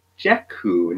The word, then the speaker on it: Dekkoo: I decided to try out a service called Deku. Now, I hope I'm pronouncing that correctly, but it's spelled D-E-K-K-O-O.